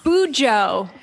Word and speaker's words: Bujo: And then Keltie reached out because she is obviously a pro Bujo. Do I say that right? Bujo journalist? – Or? Not at all. You're so, no. – Okay, so how like, do you say it? – Bujo. Bujo. Bullet journaling.